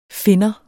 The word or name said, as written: finder